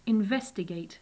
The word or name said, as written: investigate